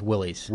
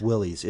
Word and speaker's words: Willys: There was a 38 Ford, and there was one from Wiley. Am I saying that right? – I think it's Willys. – Willys. It is Willys.